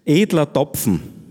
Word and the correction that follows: Edler Tropfen: Edler Tropfen, ein Bayerischer, wir hören die Sonntagsmusi.